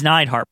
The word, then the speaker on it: Neidhart: Now he's Neidhart because the announcer said Neidhart when he announced him and he didn't want to look stupid.